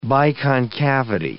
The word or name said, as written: biconcavity